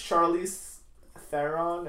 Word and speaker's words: Charlize Theron: Also, in this universe, Charlize Theron, is that how you say it? – Yeah.